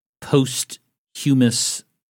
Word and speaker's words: posthumous: The governor of Missouri and asked for a posthumous pardon. – It does make you sound smarter when you really land hard on that otherwise silent H.